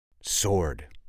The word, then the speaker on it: sword: But aisle, spelled I-S-L-E, is basically just an island, which also uses a silent S. Oh, what about the silent W? Sword. Sword. S-W-O-R-D.